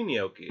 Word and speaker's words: gnocchi: Spelled with a G, gnocchi is. I think that silent G.